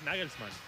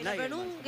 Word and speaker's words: Nagelsmann: Es Nagelsmann. – Claro, es Nagelsmann. – ¿Cómo suena? – Es Nagelsmann, pero yo consulté algunas páginas que...